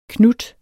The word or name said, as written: knut